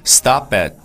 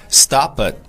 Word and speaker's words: stop at: 首先呢，我们来看一下 stop 和这个 at 这个地方，你可以把这个 stop 和 at 连起来读，叫 stop at。当然，这个 at 你在读的时候呢，它的发音不是 a 这个音，它的发音就是变成了 a a，所以你这个 stop 这个 p 和这个 a 连起来读的话呢，就读成叫 stop it。